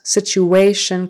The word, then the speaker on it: situation: Third syllable stress. Situation correctly before we move forward.